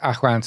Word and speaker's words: Awen: Awen, Achwen. Yeah, so it's pronounced Achwen. So it's like a kind of a Welsh, it would have been like a Celtic, so including the Welsh.